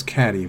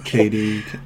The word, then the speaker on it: Katie: That was Catty. Where is it, Katie? – They're pulling a Mean Girls here. Lindsay Lohan's character's name is Katie, but it looks like it's pronounced Catty on paper.